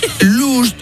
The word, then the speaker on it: lost: Porque vamos a ver, last, L-A-S-T, es... – último. – Lost, L-O-S-T, es... – perdido. – Y lost... no se pronuncia luz, sino que se dice también lost, pero last, L-U-S-T, significa lujuria.